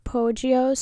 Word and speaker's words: Poggio: It's spelled P-O-G-G-I-O. We want to make Poggio.